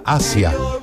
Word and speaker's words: Aja: Te cuento, el disco se llama Aja, pero en realidad se pronuncia Asia, como el continente asiático.